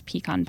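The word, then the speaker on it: Picon: And at Louis, you can also get the famous pecan punch, which is like a traditional drink. – And it's not spelled pecan like you probably think it's spelled. It's P-I-C-O-N.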